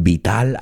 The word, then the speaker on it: vital: And vital, I think you know the word, but we pronounce it vital. Vital vehicles. Remember, Americans often pronounce that T like a D.